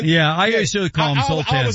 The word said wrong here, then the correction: Zlatan: Zoltan. Okay. Zlatan. Even the beef between, you can tell those two guys do not like each other.